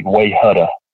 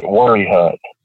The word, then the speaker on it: Wayehutta: But at the same time, you know, there are things like there's a place here that it's spelled W-A-Y-E-H-U-T-T-A, which to anybody else would look like Wayhutta. But we pronounce it Worryhut.